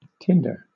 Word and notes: tinder: British English

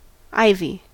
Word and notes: ivy: American English

